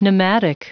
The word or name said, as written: pneumatic